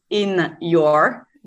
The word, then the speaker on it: Inyor: pronounce it Inyor. Yeah. Like in your body, mind, and skin, because I wanted to work more on how the skin works in a relation with the body.